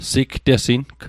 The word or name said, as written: sigtesing